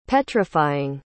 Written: petrifying

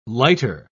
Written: lighter

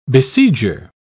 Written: besieger

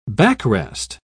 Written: backrest

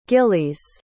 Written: gillies

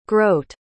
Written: grote

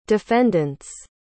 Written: defendants

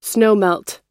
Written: snowmelt

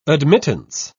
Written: admittance

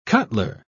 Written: cutler

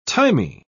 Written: thymy

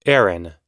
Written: Aaron

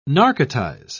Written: narcotize

